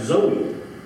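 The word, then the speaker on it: Zoe (ζωή): The word is spelt Z-O-E. Now, in our society, we say that's Zoe. Your Greek lesson is this. It's actually pronounced Zoe. Okay? With the emphasis on the A, not the Zo.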